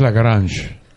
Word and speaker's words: Lagrange: Lo pronunciamos Lagrange. Lagrange. Pero es Lagrange. Es francés. En francés, Lagrange.